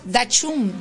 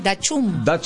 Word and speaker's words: Dachshund: Bueno, a ver, se llama Dutch... ¿Cómo se dice esto? A ver, amor... ¿Cómo lo lees vos? Dachshund. – Dachshund. – Dachshund es el perro salchicha.